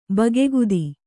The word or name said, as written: ಬಗೆಗುದಿ